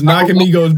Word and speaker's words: Nakamigos: Anytime you make something from Nakamigo, uh, what what was it? Hold on, I don't want to mispronounce it because it was Nakamigos, but you made.